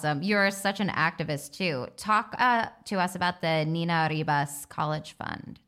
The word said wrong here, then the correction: Niñas Arribas: Did I say that right? – Yeah, Niñas Arribas. Niñas Arribas. I knew there was something I missed.